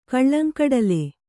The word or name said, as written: ಕಲ್ಲಂಗಡಲೆ